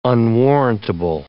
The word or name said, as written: unwarrantable